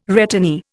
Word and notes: returnee: Indian

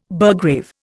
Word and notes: burgrave: Indian

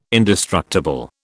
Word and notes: indestructible: British English

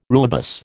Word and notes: rooibos: American English

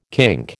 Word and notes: kink: American English

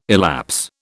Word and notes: elapse: British English

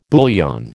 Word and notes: bouillon: American English

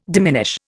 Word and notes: diminish: Indian

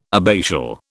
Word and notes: abbatial: British English

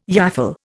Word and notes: yaffle: Indian

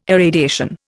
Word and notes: irradiation: Indian

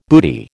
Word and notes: booty: American English

